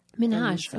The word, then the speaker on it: Minhaj: That is what, I'm going to say his name wrong every time. Hasan Minhaj. – Yeah. But he was very specific about Minhaj. – Minhaj.